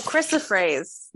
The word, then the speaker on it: chrysoprase: So I probably will say it wrong, but it's Kairos praise. I can't say it. – Oh, chrysoprase. – Yes, that's how I chrysoprase. – That's how I've always said it, but there's no PH at the end.